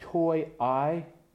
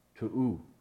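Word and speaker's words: Tou: And it says, since when it looks like toy I, but it's actually pronounced to'u. It sounds kind of funny.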